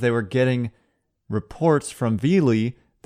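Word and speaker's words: Viele: Point to make about Vealy, I now know the name is pronounced Vealy because it's on his personal Twitter page. He clarifies the pronunciation as V-L-E-E, Vealy.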